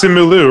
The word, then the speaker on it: Simu Liu: How did you say his name? – I don't want to say it again because I know I messed it up. – It's just Simu Liu, right? – I said CM Liu, I think.